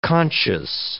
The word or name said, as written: conscious